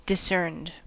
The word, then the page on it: discerned: American English